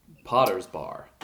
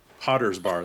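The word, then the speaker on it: Potters Bar: I think we'll do one more, and that is Stanley from Potter's Bar. Did I say that right? Sure. – Potter's Bar. – Potter's Bar.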